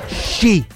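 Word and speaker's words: sci (四): Perché si pronuncia sci, tipo la canzone dei Green Day, sci.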